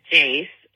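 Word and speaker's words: Jayce: My parents gave me this weird name, and I kind of have a lisp, and my name is Jace. It's J-A-Y-C-E, Jace. – Which is basically Joyce, but with an A.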